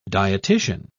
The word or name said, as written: dietitian